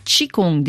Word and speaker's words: qigong: Aujourd'hui, vous intéressez à un sport traditionnel chinois, c'est le Qigong.